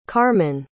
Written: karman

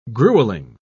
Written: grueling

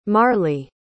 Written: marleigh

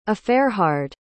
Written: eferhard

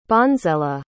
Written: bonsela

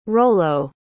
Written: rollo

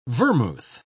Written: vermouth

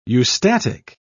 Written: eustatic

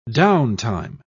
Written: downtime

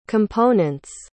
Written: components

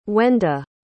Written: wenda

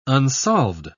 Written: unsolved

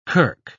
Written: kirk

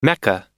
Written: mecca

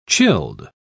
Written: chilled

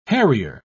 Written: harrier

